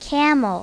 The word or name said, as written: camel